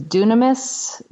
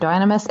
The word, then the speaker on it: dunamis: Is dunamis? I'm not saying it right. Dynamis.